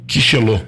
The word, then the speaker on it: Quixelô: Quixelô. Q-U-I-X-E-L-O com acento. Quixelô.